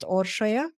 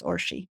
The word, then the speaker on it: Orshie: Yes, and it's pronounced Orshie, but for short, it's Orshie.